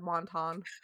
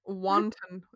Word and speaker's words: wanton: But as war approached, she began to be seen by some as a wanton. Oh my God, I said wanton. I read it in my brain right, and then I said it wrong. – Yep. – Wanton.